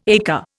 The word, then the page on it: ekka: Indian